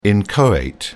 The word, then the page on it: inchoate: British English